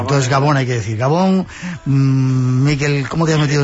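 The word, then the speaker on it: Gabon: Arrachaldeón, agachaldeón, ¿cómo es? ¿Cómo se dice? – Arrachaldeón o Gabón.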